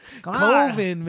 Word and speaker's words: Coven: Coven, man. Coven. It doesn't rhyme with oven. It's Coven.